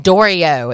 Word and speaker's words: Dorio: So this band, Dorio, it's spelled D-O-R-I-O, like Daria, but with O's instead of the A's.